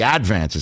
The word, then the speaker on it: Advance: You have to put the accent on the first syllable. It's the Advance.